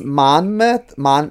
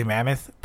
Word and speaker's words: Monmouth: I think it's pronounced Monmouth. – Mammoth? Yeah, like almost like a Willy Mammoth, but Mammoth. Like Mama. – Okay. – Mammoth. Mammoth.